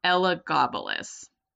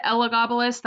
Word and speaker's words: Elagabalus: And I was actually just gonna say I pronounce it Elagabalus. That's how I've mostly heard it, but I have heard it also pronounced Elagabalus.